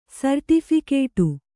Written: ಸರ್ಟಿಫಿಕೇಟು

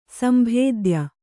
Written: ಸಂಭೇದ್ಯ

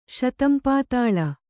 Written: ಶತಂಪಾತಾಳ

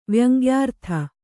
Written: ವ್ಯಂಗ್ಯಾರ್ಥ

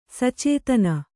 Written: ಸಚೇತನ

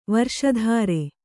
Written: ವಾರಿಶತ್ರ

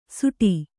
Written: ಸೂಟಿ

